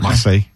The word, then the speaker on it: Marseille: during an outdoor meal in Marseille. How do I say it? – Yeah, that's pretty. Hey, you're almost there. – You don't have to go, but you know, okay. Marseille.